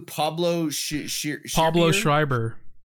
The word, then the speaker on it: Pablo Schreiber: Pablo Schreiber.